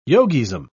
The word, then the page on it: yogism: British English